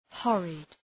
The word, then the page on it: horrid: ‘hɔ:rıd